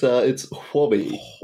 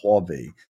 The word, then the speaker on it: Huobi: Huobi or something? – It's Huobi. – Huobi.